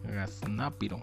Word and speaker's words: gaznápiro: Gaznápiro. Gaznápiro con Z, acento en la A. Segunda, gaznápiro. Palurdo, simplón, torpe, que se queda abobado con cualquier cosa.